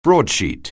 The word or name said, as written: broadsheet